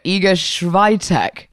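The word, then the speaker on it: Iga Świątek: How do you pronounce the name Iga Shwaya? Igashwaya Schreier. – Ah, okay, okay. No, Iga Swiatek. Yeah, okay, that's the like one.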